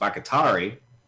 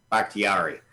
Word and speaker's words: Bakhtiari: And now the Packers, because they lost Bakhtiari. I said that wrong. – Bakhtiari. Yeah.